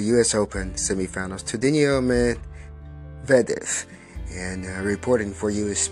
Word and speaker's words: Medvedev: Medvedev is how you pronounce his name. Finally, I get it.